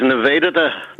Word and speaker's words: Nivedita: The proper pronunciation, I have this from Diljit Rana, is Nivedita. – Nivedita. Sister Nivedita. – Although most people would say Nivedita, but the emphasis on is wrong. It's Nivedita.